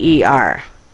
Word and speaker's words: Kruger: But the author, if you look it up in Bard, is Kruger, German spelling. So it's Kruger, which I guess if you were German would be pronounced Krager, but I don't know. I'm poor about German pronunciation anyway, but the narrator says Kruger, and I think he probably says Kruger as well.